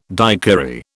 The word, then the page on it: daiquiri: British English